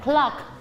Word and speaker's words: clock: ที่แล้วครูกุ้งสอนอ๋อนักเรียนบอกว่าคอกคอกคอกคอกใช่เปล่าไม่ใช่ตัวที่ออกเสียงตัว C กับตัว L ออกเสียงพร้อมๆกัน clock ลงท้ายเลเตอร์ K ด้วยใช่ไหมออบิทลงท้ายเลยเลเตอร์ K ออกเสียงพร้อมๆกัน clock clock